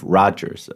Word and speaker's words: Rogers: Und eigentlich sagt man auch nicht Steve Rogers, sondern Steve Rogers. Also das O spricht man etwas anders aus.